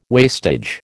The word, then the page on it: wastage: American English